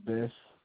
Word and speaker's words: Bess: My first name is Tyrese, and my last name is Bess.